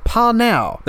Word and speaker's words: PNAU: Apparently, for a number of years, he tutored this techno music team called Panau. Their name is spelled P-N-A-U, and one of the most accepted pronunciations is Panau. So that's what I'm going to call them here, Panau, and they They started releasing albums and they came very popular in the UK.